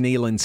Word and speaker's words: Neyland: Right, like if it is Neyland Stadium, I should think of knee.